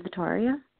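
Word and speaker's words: Vittoria: Hi, this is Victoria.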